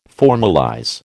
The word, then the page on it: formalize: American English